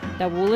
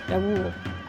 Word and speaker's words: davul: I should mention too, davul is spelled D-A-V-U-L, and in Turkish, I believe, the V and the W are a little mushed together sometimes. It's not a davul and it's not a davul, it's a davul.